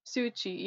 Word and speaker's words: Suu Kyi: Aung San Suu Kyi? – Suu Kyi, yeah. – Suu Kyi? – Yeah.